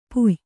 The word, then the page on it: ಪೂಯ: kannada